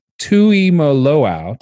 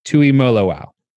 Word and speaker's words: Tuimoloau: You've got two top 10 players, JT Tuimoloau. Did I say that right or wrong? Please correct me. – You were almost there. – I want to try one more time.